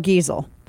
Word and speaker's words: Geisel: Now, the way that I understand it, because this is affecting like six books, and Theodore Geisel is his name, or Geisel probably is the correct way to say it.